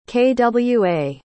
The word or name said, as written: kwa